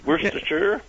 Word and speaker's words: Worcestershire: Worcestershire. I know I'm not saying that right. England. – Well, and, you were pretty close. Worcestershire. Yeah. It was just like the sauce.